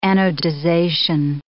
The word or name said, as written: anodization